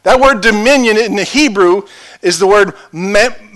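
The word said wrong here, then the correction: mamlaka (ממלכה): mamlaka. It's hard to pronounce. I don't do good with those words. It's mamlaka, which means this it means to rule, it means to reign, and to master.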